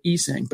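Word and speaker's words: Essang: By the way, he pronounces it Esang, but nevertheless, thanks for everything, Bassey.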